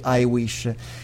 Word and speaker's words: HiWISH: del programma I Wish. Intanto vi faccio notare questo gioco di parole. Il nome del programma si scrive HIWISH e si legge I Wish.